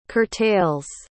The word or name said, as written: curtails